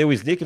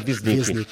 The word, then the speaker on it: Wisnik: Eu falei Wisnik, mas eu acho que a pronúncia é Wisnik.